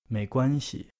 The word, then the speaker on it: 没关系: 没关系。